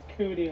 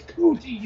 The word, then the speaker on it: Kuti: I just say Kuti like you have cooties.